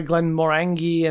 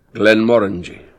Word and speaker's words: Glenmorangie: Yeah, because like there's you know, some people say Glen Morangi and stuff like that, but let's find what the actual way of pronouncing it is. – Hopefully we're right. – Glen Morangi.